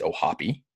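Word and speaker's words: O'Hoppe: Logan Ohapi, by the way, for those of you looking for a pronunciation, it's Ohapi, native of the Ireland tribe.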